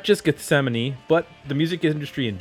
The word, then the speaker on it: Gethsemane: We're going to be talking about the album from 1993, Gethsemane.